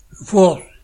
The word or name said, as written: Fours